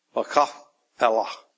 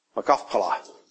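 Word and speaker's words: Machpelah: Anybody want to know how to pronounce that word? Mach. Mach. Kaf. Makafpelah. Makafpelah.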